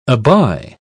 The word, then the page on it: abye: British English